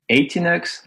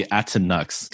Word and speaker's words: Atinux: In French, I say Atinux. – That sounds way cooler. – Yeah, because the American, I think, would be Atinux, because it's spelled A-T-I-N-U-X. But I think your way sounds way better.